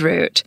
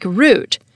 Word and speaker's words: route: And as an aside, I pronounced that word like route. Trade route. But some other Americans would pronounce it route, trade route. It turns out that route is currently the only British pronunciation, but both route and route are acceptable in American English. The British used to pronounce it both ways too, but eventually they seemed to have settled on route, while Americans remained indecisive.